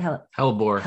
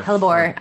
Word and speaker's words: Hellebore: And real quick before we part, we mentioned that some of the cards that you have are Dandelion and Hellebore and how do you say it? – Hellebore. – Hellebore. I always mess that one up.